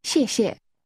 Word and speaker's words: 谢谢: 谢谢。谢谢。谢谢。谢谢。 – That's pronounced with two falling tones.